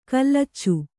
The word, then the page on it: ಕಲ್ಲಚ್ಚು: kallaccu